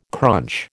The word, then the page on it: crunch: American English